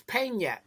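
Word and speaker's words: Peña: It's Pena, like the nya. That in that thing means like nya. Nya. Like piñata.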